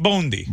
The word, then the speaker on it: Bondi: How do you say it? – Bondi. I got it now, I think.